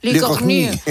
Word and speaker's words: Lecornu: Sébastien Lecornu, no sé pronunciarlo muy bien, ahora nuestra corresponsal... – Lecornu.